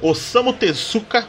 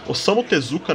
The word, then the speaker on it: Osamu Tezuka: Osamu Tezuka, Osamu Tezuka na verdade, né? Corrigindo a minha pronúncia aqui, que feio foi aí um do, dos pais do anime moderno.